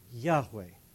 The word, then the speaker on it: Yahweh: And sometimes the name is pronounced Yahweh. Yahweh, YHWH.